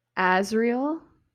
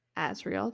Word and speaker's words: Azriel: But anyways, Cassian, okay, Cassian, Azriel, Azriel, that's how you say that one, Elain, Elain, Nesta, my girly Nesta, Amren, Morgan.